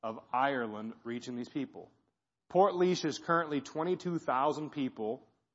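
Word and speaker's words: Portlaoise: You probably wouldn't think it was spelled Port Leash, but it's called pronounced Port Leash.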